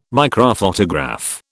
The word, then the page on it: microphotograph: British English